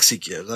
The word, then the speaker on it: Seiko: It's a Seiko. I like Seikos. I've got two Seikos. – I think you mean Seiko? – Dude, you pronounce it how you want to. I'll pronounce it how I want to.